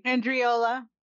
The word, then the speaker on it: Andreola: Mason, Karen Andrelia. Am I going to say it right? – Andreola.